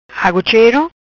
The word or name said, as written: Agu chero